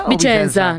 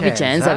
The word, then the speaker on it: Vicenza: ¿Cómo, lo pronuncio? ¿Vicenza? – Vicenza, no, Vicenza, Vicenza. Qué bonito. O sea, como Vicente Fernández.